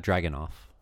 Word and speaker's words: Dragunov: We have Walter versus Dragunov. Is that how you pronounce that? – Yeah, Ilya Dragunov.